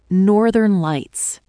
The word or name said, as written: Northern Lights